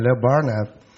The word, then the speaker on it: Leo Burnett: Leo Burnett, come la perché su Britannica io sono meno bravo, che è un'agenzia di fama mondiale.